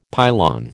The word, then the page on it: pylon: American English